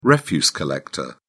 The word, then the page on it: refuse collector: British English